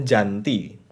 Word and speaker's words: Gianti: Gianti itu sendiri sebetulnya ejaan Belanda yang yang benar itu seharusnya Janti bukan Gianti tapi karena ejaan Belanda huruf G dibaca J